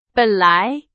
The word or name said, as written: 本来